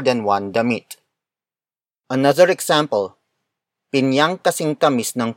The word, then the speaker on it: pinyang: Word for word, it's pinyang kasing tamis ng pulot. Pinyang kasing tamis ng pulot. Pinyang kasing tamis ng pulot. The first word is pinyang. Pinyang. Pinyang. Pinyang is spelled as P-I-N-Y-A-N-G.